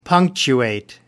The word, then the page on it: punctuate: American English